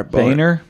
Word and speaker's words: Boehner: That can't be how you say it, right? It's not Boehner, but it's B-O-E-H-N-E-R. – Boehner, maybe?